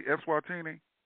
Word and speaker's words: Eswatini: Am I pronouncing that correctly, Eswatini? – Yes, you're correct.